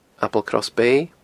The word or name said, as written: Applecross Bay